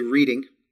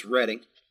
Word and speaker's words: Reading: Some of you say Reading. It's not. It's pronounced Reading.